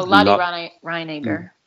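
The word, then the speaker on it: Lotte Reiniger: Oh, Lottie Reiniger.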